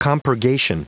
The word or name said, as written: compurgation